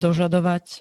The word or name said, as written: dožadovať